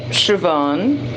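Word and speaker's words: Siobhan: É que nem é, Shivan, que é S-I-O-B-H-A-N, ou Saoirse, que é o nome daquela outra atriz também irlandesa, que é Sursa.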